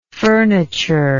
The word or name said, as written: furnitureless